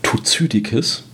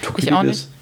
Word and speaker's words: Thukydides: Hippokrates als Vater der Medizin bezeichnet, äh, dann die beiden Historiker Herodot, ja, und vom Sprecher von Maestro so ausgesprochen Thucydikes. Die Aussprache habe ich so noch nie gehört. Okay, aber es geht Thukydides.